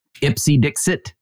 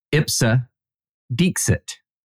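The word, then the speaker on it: ipse dixit: Ipsy Dixit, which is how I pronounce it. That is not how it's pronounced. I know that comes as a surprise to you, Sarah, but it is Ipsa Dixit.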